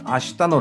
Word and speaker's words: 未来: えー、海運ワクワクコンパス、ね、未来と書いて明日と読ませますけど、明日